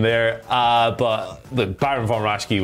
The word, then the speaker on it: Raschke: You've got, I'm going to butcher this, Baron Von Raschke. – Raschke, yes.